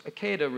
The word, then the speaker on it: Akedah: It's Akedah, really. It rhymes with cicada.